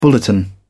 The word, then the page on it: bulletin: British English